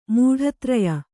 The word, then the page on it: ಮೂಢತ್ರಯ: mūḍha traya